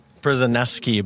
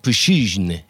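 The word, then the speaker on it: Przysiężny: You've got the doubles player, Michael Przesnesky, but that's Americanized. – Right. So you say it's Mikhail Pushizhny. – Pushizhny. Exactly.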